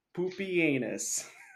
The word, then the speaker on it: Pupienus: You will love an emperor they use called Pupienus, who is his name. So that's how you pronounce again, it. – That or is like actually how you correctly Pupanus? Pronounce it, Pupienus.